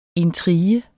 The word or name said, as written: intrige